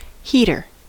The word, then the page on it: heater: American English